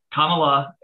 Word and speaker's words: Kamala: Is it Kamala or Kamala? – So it's kind of complicated. So it's Kamala is I think the preferred way to say it. I think I pronounce it the Sanskrit way.